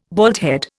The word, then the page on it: baldhead: Indian